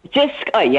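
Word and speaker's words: JYSK: Is it Jisk? – Jisk, yeah.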